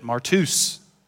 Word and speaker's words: martus: The Greek word for witness is pronounced, the root of it, martus, which comes into English, transliterated, as martyr.